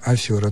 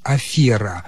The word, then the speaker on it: афера: Никогда не было нормативным произношения афера, только афера. Поэтому убеждение в том, что раньше правильно было афера, это просто заблуждение.